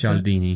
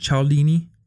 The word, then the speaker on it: Cialdini: Is that how you pronounce Cialdini. – That? – Cialdini. – Cialdini. I still don't have the right accent.